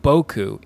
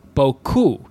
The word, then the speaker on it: BoKu: There is a very clear emphasis, a stressed syllable on the ku of BoKu. So it's not BoKu, it's BoKu.